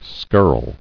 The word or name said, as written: Skirling